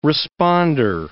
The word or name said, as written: responder